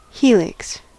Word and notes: helix: American English

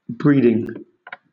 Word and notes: breeding: British English

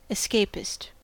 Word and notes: escapist: American English

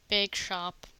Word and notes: bakeshop: American English

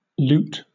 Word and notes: loot: British English

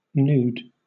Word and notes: nude: British English

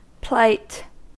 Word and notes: plight: American English

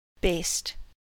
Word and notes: baste: American English

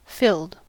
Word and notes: filled: American English

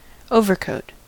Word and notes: overcoat: American English

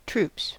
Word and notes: troops: American English

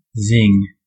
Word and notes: zing: American English

